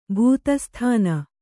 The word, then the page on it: ಭೂತಸ್ಥಾನ: kannada